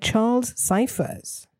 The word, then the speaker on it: Charles Cyphers: And Charles Cyphers, who is Sheriff Brackett.